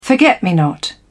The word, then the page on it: forget-me-not: British English